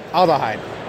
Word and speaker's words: Aldehyde: Hey, Voyagers, we're here with Aldell Hyde. Thank you for telling me how to pronounce that. Was that right? Aldehyde. Okay, I'll get that right.